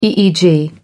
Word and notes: EEG: American English

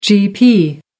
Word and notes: gp: American English

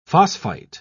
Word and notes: phosphite: British English

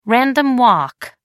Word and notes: random walk: American English

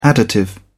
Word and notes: additive: British English

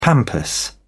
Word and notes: pampas: British English

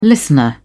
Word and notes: listener: British English

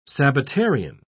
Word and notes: sabbatarian: British English

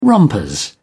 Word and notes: rompers: British English

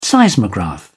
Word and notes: seismograph: British English

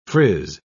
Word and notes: friz: British English

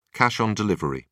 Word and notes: cash on delivery: British English

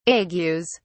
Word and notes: agues: British English

